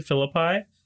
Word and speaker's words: Philippi: A lot of people pronounce it Philippi, and that just doesn't feel right. – I'm trying to think of other Greek cities that end in an I, and I can only think of, well, this is Italian, Pompeii. – Right. – Which... – No, an I at the end of the word is not pronounced I, it's E.